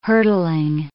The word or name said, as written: hurtling